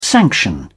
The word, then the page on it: sanction: British English